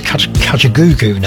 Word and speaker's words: Kajagoogoo: We've Yazoo all ready to play for you very shortly, but first of all, here's Kajagoogoo now.